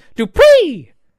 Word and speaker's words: Dupri: Well, I want you to say his name right the next time we do a show. It's Max Dupri. – Max and I will Dupree.